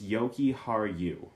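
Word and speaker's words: Jokiharju: I know it's Yoki Haru with the two yuz, the two, like, soft J sounds.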